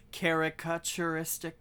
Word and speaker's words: caricaturistic: Okay, so wouldn't it be caricaturistic? – That's what I'm pronouncing. You're just missing the sort of de-emphasized caricature. It's a load-bearing ka.